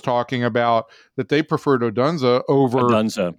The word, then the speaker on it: Odunze: Um, is it Odun Odunze? How do you pronounce? Yeah, that? Rome Odunze.